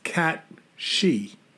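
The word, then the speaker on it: Cù Sìth: So it's C-U-S-I-T-H, but they say it's pronounced Cat-She.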